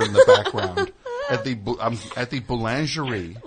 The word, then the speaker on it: boulangerie: You go to the boulangerie.